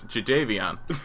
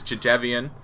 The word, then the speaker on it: Jadeveon: And Fallon's like, okay, how do they pronounce it? And he goes, Jadeveon. So, it is Jadeveon.